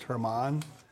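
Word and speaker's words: Germán: I mean, his name looks like German, the way it's spelled, G-E-R-M-A-N, but he's Hispanic and it's pronounced Herman.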